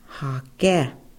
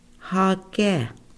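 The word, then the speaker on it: Haké: For the fifth-born male within a family, his name was Hake, Hake. Okay, we want to pronounce that together. The fifth-born male, Hake. Okay, now for our females, the very first-born daughter, she was named Winuna, Winuna.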